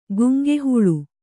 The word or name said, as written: ಗುಂಗಿಹುಳು